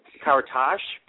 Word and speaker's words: Caritas: Actually, the Caritas, but there's no H at the end, so you actually pronounced it perfectly the way it's written, you know, better than most would, so yeah. And my legal first name actually is Sudi, S-U-D-I, so in case my parents are listening, you know, I have to throw that in.